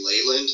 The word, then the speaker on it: Leland: It's supposed to be Leyland, sort of like ley lines.